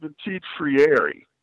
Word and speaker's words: Petit-Ferrier: I pronounce it Petit-Ferrier. I actually pronounce the E as a long E, but between the two of us, I think we're about there, so it's all right.